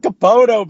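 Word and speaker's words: Kapono: His last name rhymes with Bopono. – I knew. Jason Capono, baby.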